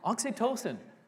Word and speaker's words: Oxytocin: It releases, they think it releases this thing called I gotta say it right because the other ones are addictive. Oxytocin, okay? Not OxyContin or that kind of thing.